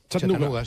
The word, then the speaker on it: Chattanooga: ¿Se pronuncia así en Chattanooga? – Chattanooga, sí. Chattanooga.